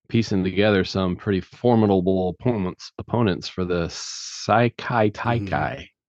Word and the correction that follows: Sakai Taikai: I think it's Sakai Taikai.